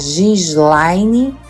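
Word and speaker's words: Ghislaine: É muito fácil, é Gislaine Cantini. Sendo que o meu nome se escreve GH Gislaine Cantini.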